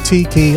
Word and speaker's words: Teki: And the track's called Tiki, that's T-E-K-I. Or it could be pronounced Teki. I prefer Tiki.